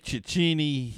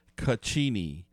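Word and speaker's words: Cecchini: I always say Ciccini. He said it's pronounced Caccini.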